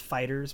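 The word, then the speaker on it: FighterZ: It's pronounced Fighters, but they capitalize the Z.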